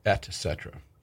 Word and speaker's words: et cetera: You're saying that correctly, by the way, et cetera.